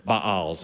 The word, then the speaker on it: Baals: Then the Israelites did evil in the eyes of the Lord, and they served the Baals, or Baals is the actual pronunciation, but essentially these false gods.